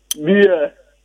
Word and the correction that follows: bear: And then the animal is pronounced bear, bear.